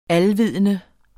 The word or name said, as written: alvidende